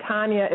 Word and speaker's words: Tanya: And I only say this because people think that Tanya is pronounced Tanya because it has a long A, but it's Russian C. And so the A is really the short A.